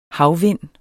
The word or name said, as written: havvind